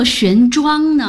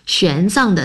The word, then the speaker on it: 玄奘: 我一直以为读作玄奘呢。 – 玄奘的藏上面是强壮的壮的繁体字字形，下面是大小的大，上下合起来是壮大。 – 是的，藏的意思正是壮大，用于人名如唐代的玄奘。